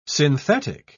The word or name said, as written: synthetic